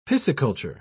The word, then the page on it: pisciculture: British English